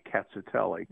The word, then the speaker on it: Rkatsiteli: Well, I think the ones that are going to be the easiest to find are a white wine called Cazzatelli, which is actually starts with an R. It's spelled R-K-A-T-S-I-T-E-L-I, Cazzatelli.